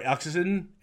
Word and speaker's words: Eccleston: We got Christopher Eccleston.